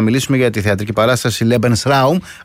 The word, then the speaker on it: Lebensraum: Lebensraum το έχουμε, είναι ακουστικά σε εμάς, αλλά η πιο σωστή προφορά είναι Lebensraum, προς τη γερμανική εκφορά του λόγου.